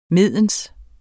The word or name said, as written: mens